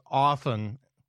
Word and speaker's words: often: I knew that my kids became more sophisticated than me when they started pronouncing the T in often. Like, hey, Dad, how often are you going to hit us up for money? In my house, it's often with no T, and I only hit you up for money as often as I need to post bail.